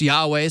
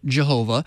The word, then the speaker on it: Yahweh: Y-W-H, that nobody can pronounce, but it's sometimes pronounced Yahweh, sometimes pronounced Jehovah.